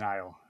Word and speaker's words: Nile: And she was kidnapped as a child by Martian Rose's father, who was the original Eye of the Nil. Of the Nile.